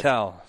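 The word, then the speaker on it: Fattal: Fattel. – How do you spell that? – F-A-T-T-A-L. F as in Frank. – Jack Fatal. – Like Fatal. – Fatal. – Like Fan Fatal.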